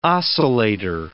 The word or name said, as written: oscillator